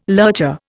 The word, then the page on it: lurcher: Indian